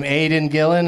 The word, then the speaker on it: Aidan Gillen: Aidan Gillen, is that how you say his name? – Yeah.